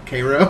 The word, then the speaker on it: Cairo: It's just like the Georgia City, like, C-A-I-R-O, Cairo.